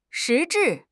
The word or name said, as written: 实质